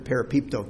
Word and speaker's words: parapipto: It's P-A-R-A-P-I-P-T-O, parapipto. I'm obviously pronouncing that wrong. I'm not Greek. P-A-R-A-P-I-P-T-O, parapipto.